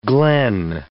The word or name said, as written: glen